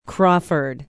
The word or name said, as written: crawford